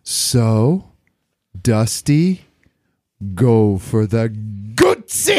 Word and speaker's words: Guzzi: Emma, will you teach him how to say that right? – Guzzi. – There you go.